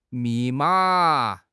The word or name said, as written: mi4 ma3